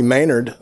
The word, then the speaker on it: Manard: My name is uh, Tony Manard, and uh, it's pronounced like it has a Y in it, but it doesn't.